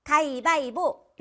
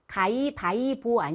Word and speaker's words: 가위바위보: 가위바위보! 이거 많이 하죠? 네. 가위바위보 아니고요. 가위, 바위, 보. 이렇게 발음하셔야 돼요.